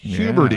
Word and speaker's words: Huberty: Rhyming with puberty. Huberty.